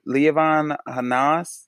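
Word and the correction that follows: Lianne La Havas: You talking about Leanne Le Havas?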